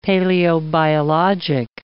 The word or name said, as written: paleobiologic